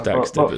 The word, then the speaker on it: Daxter: I'm assuming it was Jack from Jack and Dexter. But I thought that's what. – Oh, Daxter, meant. Even if I say it right, yeah. It's not Dexter, is it? It's Daxter, but still.